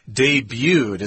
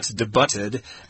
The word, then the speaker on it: debuted: When he debuted. It's not debuted, but debuted. – Hey, yeah. – That's the T is silent there, so it's kind of weird.